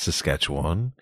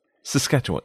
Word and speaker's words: Saskatchewan: I think I said Saskatchewan, but now I think I would modify it to Saskatchewan. Saskatchewan. – But see, I'm still hearing four syllables. – Saskatchewan. Yeah, I mean, I think they're still there, but you kind of slide right. – those together. – Sort of like the word P-O-E-M.